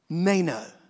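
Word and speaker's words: meno (μένω): In the Greek, it would have been pronounced meno, and it means to remain, not to depart, to continue to be present, to be held and kept continually.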